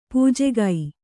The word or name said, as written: ಪೂಜೆಗೈ